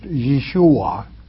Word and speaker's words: Yeshua: Yahweh, V-E-H, is God's name. That's the Father's name, Yahweh. Jesus is Yahweh's Savior, pronounced Yeshua in the Hebrew tongue, all right? Yahweh the Father. You know, W-E-H is very wrong.